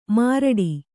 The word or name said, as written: ಮಾರಾಟ